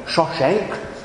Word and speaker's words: Shishak: The Pharaoh Shishak, the Egyptian pronunciation is more like Shoshank, but you know how it goes when you take a word from one language into another. It can sometimes do some very interesting things with the vowels.